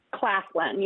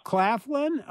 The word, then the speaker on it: Claflin: The first white residents of Door County were Increase and Mary Chaflin, I think that's pronounced right. – Claflin, yes. – Claflin? Oh, yes. Okay. It seemed like a weird spelling, so Claflin.